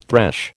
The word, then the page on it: thresh: American English